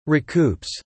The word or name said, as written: recoups